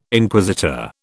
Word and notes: Inquisitor: British English

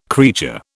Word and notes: creature: British English

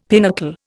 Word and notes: pinnacle: Indian

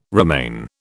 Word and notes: remain: British English

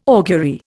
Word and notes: augury: Indian

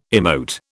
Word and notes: emote: British English

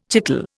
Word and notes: chicle: Indian

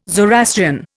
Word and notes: Zoroastrian: Indian

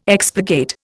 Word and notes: expurgate: Indian

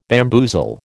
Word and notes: bamboozle: American English

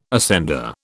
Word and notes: ascender: British English

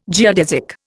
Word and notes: geodesic: Indian

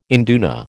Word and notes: induna: American English

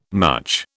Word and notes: mutch: British English

